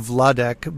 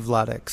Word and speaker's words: Vladeck: my name is Andrew Vladek, but my last name is spelled V as in Victor, L-A-D as in David, E-C as in Charlie, K, King. Andrew Vladek.